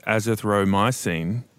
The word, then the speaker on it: azithromycin: He recommended taking it in combination with azithromycin, if I'm pronouncing it correctly.